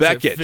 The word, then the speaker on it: Bekic: My last name, you could pronounce Beckett, 50 different ways, right? I always tell people there's like an H at the end of it.